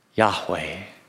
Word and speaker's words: Yahweh: And the best guess that modern Bible scholars have as to how that name might have been pronounced is Yahweh.